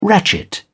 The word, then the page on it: ratchet: British English